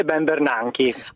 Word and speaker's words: Ben Bernanke: Alan Greenspan e Ben Bernanke.